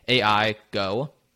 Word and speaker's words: Aigo: We get into what specifically he's doing at his company, AI Go. Dot io, I think, otherwise pronounced Aigo.